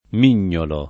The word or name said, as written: mignolare